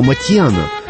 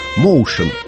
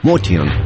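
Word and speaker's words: Motian: В первые годы 49 минут джаза я произносил его фамилию как Моушен. Потом великий знаток джаза, живущий нынче в Бостоне, Сабиржан Курмаев, написал мне, что Пол армянских кровей и что его фамилия и звучит по-армянски Матьян.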